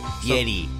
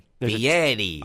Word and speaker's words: Fieri: Pronounce it right. Fieri. – Fieri. – Fieri. – Fieri. – Fieri. – I don't hear the difference. – It's Italian. Fieri.